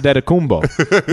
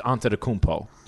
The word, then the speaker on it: Antetokounmpo: Giannis Adetokounmpo, which is the Nigerian way to say his last name, or you can go how they say it in Greece, which is Antetokounmpo.